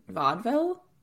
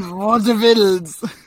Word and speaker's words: vaudeville: Everyone remembers way back when we did 3 episodes of history, theater history, you might remember that we talked about comedy in vaudeville, which is totally not how you say it, because it's that would be like a French like or comedy in vaudevilles. – Less Scandinavian and more French.